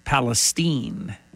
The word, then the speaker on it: East Palestine: spelled like Palestine, but it's pronounced Palestine, not E-E-N, but I-N-E.